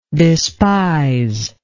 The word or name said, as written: despisingly